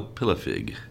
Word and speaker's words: Pilafig: Is it Pilafrigu? – No, Pilafig. – Pilafig. – Pilafig. – That's much easier. Yeah, versus the way it's spelled.